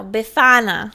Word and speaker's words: Befana: So they've got this witch named Befania. – No, Befana.